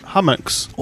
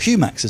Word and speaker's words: Humax: Hummux. – Or Humax, as it's more correctly pronounced. – Spelled H-U-M-A-X.